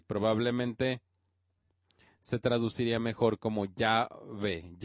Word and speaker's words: Yahvé: No sabemos exactamente cómo se pronuncia porque nada más hay cuatro consonantes en el texto hebreo, el tetragramatón para esas cuatro letras. Pero pensamos que se pronunciaba Yahvé.